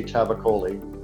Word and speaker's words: Tavakoli: And if you want to see me on Twitter, I am OK Tavakoli. That's O, letter K, and then T-A-V-A-K-O-L-I. – And I seem to be mispronouncing your surname as Tavakoli the whole time. I do apologize. It's Tavakoli, everybody who wants to actually speak to you.